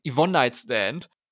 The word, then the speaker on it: Yvonne Nightstand: Apropos Yvonne Nightstand, weil ich glaube, ich habe die ganze Zeit ihren Namen falsch ausgesprochen. Ich betone das halt immer auf Nightstand, weil es halt Nachnamenbetonung ist, aber ich glaube, man müsste es so in einem Rutsch aussprechen. Yvonne Nightstand.